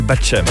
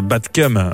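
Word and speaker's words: Bed Chem: Et puis cette fois-ci, ce titre, il est nouveau et il s'appelle Batchem. Prononcé d'ailleurs même Batcum sur oui c'est mieux.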